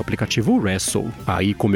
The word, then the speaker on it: Resso: Bom, e agora eu vou falar sobre a ByteDance, que lançou na Índia uma plataforma de streaming de música chamada Wrestle, que se escreve R-E-S-S-O.